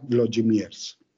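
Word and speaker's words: Włodzimierz: En polaco se utiliza con una W al final, una DZ al centro y al final con una Z, de tal suerte que se pronuncia Vlodimirs.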